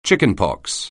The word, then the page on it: chickenpox: English